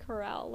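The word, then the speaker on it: Carell: It, it's pronounced Carroll, Like I know, I know it is.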